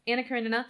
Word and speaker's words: Anna Karenina: Anna Karenina.